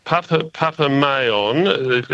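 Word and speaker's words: Papa Meilland: Papa Mayon, it's pronounced, because Mayons are the famous French breeders, probably the most famous rose breeders in the world.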